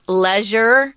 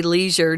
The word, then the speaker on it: leisure: I've also heard this word pronounced leisure. Most of us in America America say leisure, just so you know.